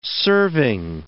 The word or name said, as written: serving